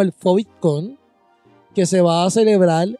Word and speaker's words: PHOVIDCON: FOVITCON se escribe P-H-O-V-I-D-C-O-N.